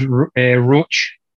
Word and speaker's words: Roach: It is pronounced Roach. – Roach. – Like Roach. – Yes, the one. – Oh, wow. Right, cool. Right. We were all wrong. I thought it was Roche.